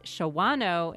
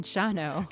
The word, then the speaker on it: Shawano: And Althea says, I used to crack up laughing every time, the weather radio said Antigo and Shawano instead of Antigo and Shano.